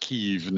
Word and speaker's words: Kyiv: If you're wondering why the 40-mile convoy headed towards Kyiv, which I will pronounce it Kyiv now,